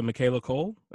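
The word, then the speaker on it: Michaela Coel: Shout out to Michaela Cole.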